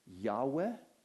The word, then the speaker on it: Yahweh: And when we put vowels between those consonants, it's often pronounced as Yahweh, or sometimes people have pronounced it as Jehovah.